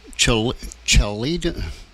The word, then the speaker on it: Chaldeans: Chaldeans.